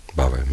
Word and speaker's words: bhava: In fact, the Pali word for emotion, bhava, it's like bhava. Bhava means becoming. Then you make it bhava with a long A.